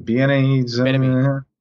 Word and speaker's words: Bieniemy: Bieniemy.